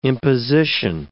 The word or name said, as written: imposition